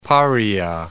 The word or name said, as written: Paria Peninsula